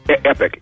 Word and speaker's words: Epoch: By the way, I want to tell everybody else, too, make sure that you are watching viewing videos for the, do you call it Epoch or Epic? – Epic, E-P-O-C-H. Epic. – I hear people, some pronouncing it E-P-O-C-H as in Epoch, Epoch Times. So Epic Times, Epoch Times.